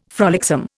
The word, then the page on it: frolicsome: Indian